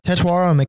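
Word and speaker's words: Tetairoa: How do you say that receiver's name out of Arizona? – Tetuaro McMillan. – Tetuaro.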